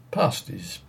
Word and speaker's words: pasties: We pronounce them pasties in our peculiar Plymouthian dialect, with a drawn-out but short A, as Northerners pronounce the A in Bath.